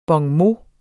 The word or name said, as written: bonmot